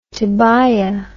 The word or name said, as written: Tobiah